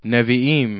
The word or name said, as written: niviim